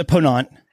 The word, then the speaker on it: Ponant: Luxury cruise line Ponant, and am I pronouncing that right? Is it Ponant? – It's a Ponant. – Ponant. Okay.